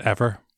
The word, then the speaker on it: Eimear: it should have been pronounced Ever. But Ever, yeah, because you've got the M there, which, like the original spelling, the Eimear that was the wife of Cú Chulainn, that was spelled E-M-E-R, which is now seen as more of the anglicised version.